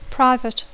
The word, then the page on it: private: English